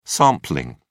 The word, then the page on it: sampling: British English